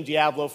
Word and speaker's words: Diablo: I see you're another man of good taste that knows it's pronounced Diablo.